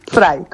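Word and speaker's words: Vrije: la Verige Universiteit. ¿Lo he dicho bien? – Frey, como libro.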